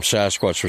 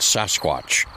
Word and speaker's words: Sasquatch: is, it's where the name Sasquatch was first coined.